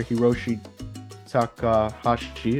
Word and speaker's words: Hiroshi Takahashi: You mentioned the Japanese player, Hiroshi Takahashi, is how you say it. So for the ones who don't know how to pronounce that, there you have it, right-handed pitcher.